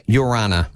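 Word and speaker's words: Ia ora na: To say hello in Tahitian, you say it's I-A-O-R-A-N-A. Pronounced like Urana.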